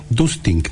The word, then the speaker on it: Dustink: Dustink, D-U-S-T-I-N-K, Dustink.cl, más fácil, miproyecto.cl, y solicito una visita técnica.